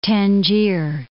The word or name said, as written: Tangier